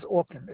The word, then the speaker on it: Orkin: My last name is Orkin. It's spelled O-R-K-I-N.